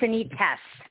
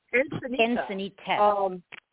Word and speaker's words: Encinitas: is it Encinito is what it is? Is that how it's pronounced? – Encinitas. – Encinitas. – With an S.